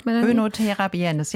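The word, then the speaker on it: Oenothera biennis: Wir sprechen über die Onothera biennis, habe ich es richtig ausgesprochen? – Onothera biennis, ja.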